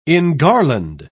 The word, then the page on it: engarland: British English